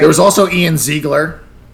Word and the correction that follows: Ziering: I can't pronounce his name. – Ian Ziering. He pronounces it Ian Ziering.